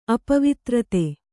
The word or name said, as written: ಅಪವಿತ್ರತೆ